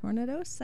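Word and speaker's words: tournedos: Tornados, I believe is how it's pronounced. It's not tornadoes, but it's spelled very similarly.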